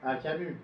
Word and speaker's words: Camus: And Comer, he was from Algeria. Comer, I don't know how to pronounce, sorry. – Camus, Albert.